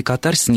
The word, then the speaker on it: катарсис: что слово катарсис, ударение должно ставиться только на первом слоге, а в большинстве европейских языков и в древнегреческом ударение ставилось на второй. Я специально забрался вчера в словарь Вейсмана древнегреческо-русский, и действительно в древнегреческом языке ударение ставилось на второй слог, катарсис. В английском это слово произносит ударение на второй слог, остальное я не успел проверить. Но, Нина, значит, если говорить о норме, о современном русском литературном ударении, то все-таки постановка на второй слог ударения считается ошибкой.